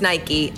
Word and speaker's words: Nike: It's Nike, I'm sorry. Está Actually, not sorry, that's just how you say it.